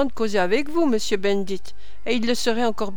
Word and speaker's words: Bendit: Monsieur Fabry, l'ingénieur des constructions, Monsieur Montbleu, le chef comptable, Monsieur Bendit, le commis pour la correspondance étrangère. Si vous parlez jamais à celui-là, ne manquez pas de l'appeler Monsieur Bendit. C'est un Anglais qui se fâche quand on prononce bandit, parce qu'il croit qu'on veut l'insulter comme si on disait voleur.